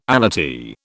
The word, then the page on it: allottee: British English